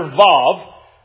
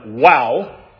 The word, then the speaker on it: vav: And it begins with a single Hebrew letter, vav, You'll hear some people pronounce this letter "wow." I was taught to pronounce it "vav" because of the German influence on Hebrew and Judaic linguistic texts. And, you know, at least in English, "vav" sounds better than "wow."